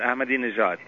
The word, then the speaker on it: Ahmadinejad: What's his name? Ahmadinejad? – Yeah, the president, Ahmadinejad, yeah.